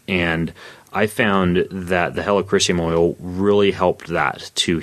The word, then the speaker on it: helichrysum: It's spelled H-E-L-I, like helicopter, and then chrysium, C-H-R-Y-S-I-U-M. But helichrysum oil works really well, especially for scars.